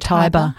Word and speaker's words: Tiber: We've got Dominic Allen, who has he's written and directed a film called Tiber, as in the river Tiber. Perhaps I'm saying it wrong. – Tiber.